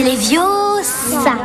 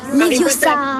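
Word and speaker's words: Leviosa: Et puis d'ailleurs, tu ne sais même pas le prononcer. C'est Léviosa. Et pas Léviosa.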